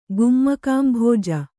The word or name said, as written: ಗುಮ್ಮಕಾಂಭೋಜ